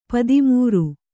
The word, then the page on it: ಪದಿಮೂರು: padi mūru